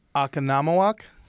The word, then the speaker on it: Oconomowoc: Oconomowoc. – Oh, my God. Just had a stroke and actually said the word right.